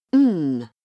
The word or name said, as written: ng